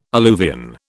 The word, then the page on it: alluvion: British English